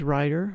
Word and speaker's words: Ryder: Discount code is Ryder, my last name spelled with the Y, R-Y-D-E-R, and then Bill with one L, B-I-L, and then the word friends, plural.